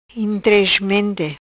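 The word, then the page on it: Intresmente: Patuá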